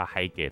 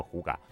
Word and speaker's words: hygge: 它其实写作 hygge 哈，它不叫 hygge，它在丹麦那边念作 huga。